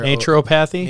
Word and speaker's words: naturopathy: Did I say that right? N-A-T-U-R. – Naturopathy? – Naturopathy, which stresses natural health care.